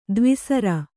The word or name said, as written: ದ್ವಿಸರ